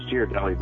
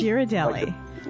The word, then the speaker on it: Ghirardelli: It's Ghirardelli, by the way. – It's Ghirardelli? – Ghirardelli. – Like the chocolate, Ghirardelli.